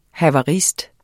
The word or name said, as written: havarist